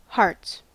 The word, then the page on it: hearts: American English